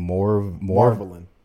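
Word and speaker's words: Marvalyn: The girl is named Marvelin. Like marvelous? Marvelin.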